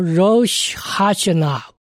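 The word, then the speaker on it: Rosh Hashanah (ראש השנה): 这个犹太新年通常希伯来文它的发音叫做 Rosh Hashanah，时间呢大概在我们公历的九月或者十月，也就是犹太历的尼斯提月初一和初二的时间。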